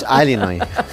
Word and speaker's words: Illinois: Es un estado de Estados Unidos que se pronuncia así. Es sí, Illinois, pero es Alinoy.